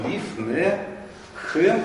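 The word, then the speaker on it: Лифнехэм (לפניהם): Лиф-не-хэм и лиф-не-гэм. – Лиф-не-хэм и лиф-не-гэм. – Да. Во втором и в третьем со множеством числа будет лиф-не.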